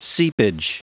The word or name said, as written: seepage